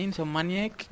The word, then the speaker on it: Insomniac: Insomniac Games.